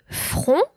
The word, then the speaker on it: front: If you think about derived word frontal, front in English, you would notice that there should be a final T, a silent T at the end of this this noun.